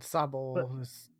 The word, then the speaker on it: Sobble: And Sobble rhymes with Wobble, which Wobbuffet.